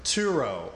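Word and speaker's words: Tsuro: Turo.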